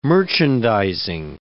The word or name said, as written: merchandizing